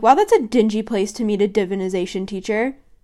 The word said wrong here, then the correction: divination: Divination! I can't say that correctly.